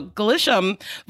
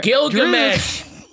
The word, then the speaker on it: Gilgamesh: Ajax offers to wipe Thena's memory, but, you know, Glisham volunteers to look over her. – Gilgamesh!